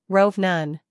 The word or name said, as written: rove